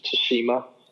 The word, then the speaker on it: Tsushima: Oh, am I saying that correctly? I'm still not sure. – I've been listening to where they pronounce it a lot, and I think it's Tsushima. – Tsushima. – Yeah, I thought it was Tsushima, but it's Tsushima. We'll go with that.